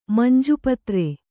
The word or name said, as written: ಮಂಜುಪತ್ರೆ